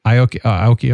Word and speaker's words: Aoki: Yeah, Steve, how do you pronounce it? Aoki or? – Aoki.